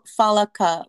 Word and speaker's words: falaka: I've always heard falaka, but we had a guest who was native to the Middle East, and I believe he pronounced it falaka.